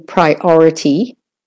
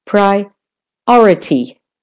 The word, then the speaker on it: priority: And the or in priority is the stressed syllable. Priority.